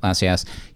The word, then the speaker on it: Assayas: And then for the most recent restoration, which I actually saw at TIFF with the director in appearance, Olivier Assayas, is that how he pronounces? – Yeah, Assayas.